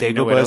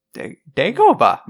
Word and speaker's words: Dagobah: So Dagobah is a force sensitive rock. – No, you said it wrong every single time. You, like, refuse to say it correctly. – Dagobah!